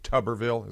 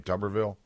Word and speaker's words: Tuberville: I'm being told that I'm mispronouncing his name. Okay. I know that's very important. Tuberville. Is his name Tuberville? Okay. That makes everything better.